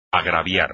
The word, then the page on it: agraviar: espagnol